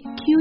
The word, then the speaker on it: queue: 还有比如说排队 q u e u e 这个单词会长得很奇怪，所以大家可能会不知道要怎么读，其实它就读 q，非常的简单。